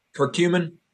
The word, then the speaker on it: curcumin: is cumin, or have you heard of that? – Curcumin.